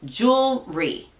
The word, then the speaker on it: jewelry: Ahí claramente son dos sílabas. Jewelry. Pero si te tomas un poquito más de tiempo, sí dirías jewelry. Jewelry. Está muy sutil, pero estás alargando un poquito esa primera sílaba.